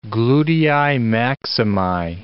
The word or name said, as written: glutei maximi